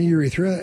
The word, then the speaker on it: urethra: urethra. Is that how you pronounce it? – Yeah, the urethra, and that's why sometimes urethra.